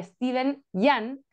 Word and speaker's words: Steven Yeun: que son Ali Wong y Steven Yeun, que comentábamos antes de comenzar a grabar, que se pronuncia así, lo dijo él mismo, lo comentó en un video que vi por ahí.